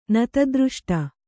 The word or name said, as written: ನತದೃಷ್ಟ